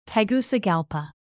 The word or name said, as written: Tegucigalpa